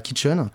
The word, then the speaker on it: Kitchin: Et j'ai créé donc la Kitchen, donc qui qui se prononce comme la kitchen en anglais, mais qui, s'écrit K-I-T-C-H-I-N.